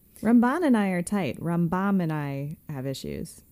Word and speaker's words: Rambam: Rambam, emphasis on the first syllable when they're talking about Maimonides.